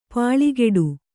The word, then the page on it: ಪಲ್ಕಡಿ: Kannada